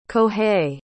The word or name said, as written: kohei